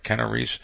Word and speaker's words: Keanu Reeves: I think of Keanu Reeves, right? Am I saying his name right? Keanu Reeves? – Keanu Reeves.